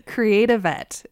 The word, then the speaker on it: Creative Vet: So the program is called, as Doug says in his article, Creative Vet. Is that, am I saying that correctly? – Yes. As in like creative vet, but kind of smushed together.